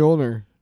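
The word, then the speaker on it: Mjölnir: Mjolnir.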